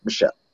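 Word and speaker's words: Michel: You stop Sony Michael. I forget the exact pronunciation. Sony Michelle. – Michelle, okay, thank you.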